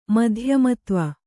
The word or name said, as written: ಮಧ್ಯಮತ್ವ